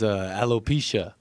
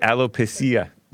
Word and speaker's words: alopecia: How do you pronounce it? – It's alopecia. – Alopecia. And I always read it alopecia.